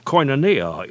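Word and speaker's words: koinonia (κοινωνία): Notice the first syllable of koinonia. It sounds like our English word coin.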